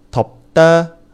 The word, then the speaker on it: 덮다: 어, 제일 먼저 알아볼 단어는 덮다예요덮다 피읍 받침이지만 말로 발음을 할 때에는 비읍으로 발음을 해야 해요. 그래서 발음할 땐 덥다 라고 발음해야 돼요. 날씨가 덥다 라고 할 때 덥다와 발음이 똑같아요.